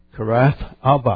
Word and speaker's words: Kiriath Arba: And they say it's the Kiriath Arba. Kiriath Arba. I say, where's the TH? Where's the H sound? They don't pronounce the H, so I don't know. Kiriath Arba is a Hebron.